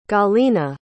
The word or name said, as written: galiena